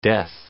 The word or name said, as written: death